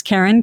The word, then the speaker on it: Karen: It's Karen, K A R E N.